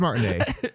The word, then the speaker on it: Martinet: It's Martinet. That's how he pronounces it.